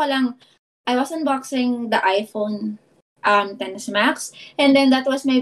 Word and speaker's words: iPhone XS Max: And then people were bashing me kasi I was pronouncing it wrong. It was supposed to be iPhone 10s Max but not iPhone XS Max.